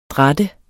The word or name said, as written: dratte